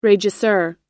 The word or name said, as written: regisseur